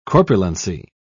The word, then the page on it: corpulency: British English